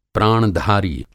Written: प्राणधारी

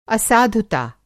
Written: असाधुता

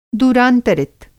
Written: दूरांतरित

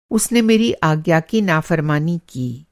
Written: नाफ़रमानी

